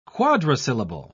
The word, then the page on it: quadrisyllable: British English